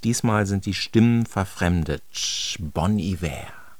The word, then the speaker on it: Bon Iver: Weiter jetzt mit Justin Vernon, der sich Bon Iver nennt, geschrieben B-O-N-I-V-E-R. Eigentlich dachte ich immer, ja, Bon Iver, aber mit falschem Französisch, benannt nämlich nach Bon Iver, guter Winter. Und er spricht sich tatsächlich in seinem Künstlernamen Bon Iver, geschrieben aber wie Bon Iver.